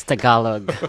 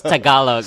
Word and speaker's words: Tagalog: It's pronounced as Tagalog. – Thanks. – I do not speak that. I'm going to have you guys teach me some Tagalog and some Vietnamese. – It's Tagalog.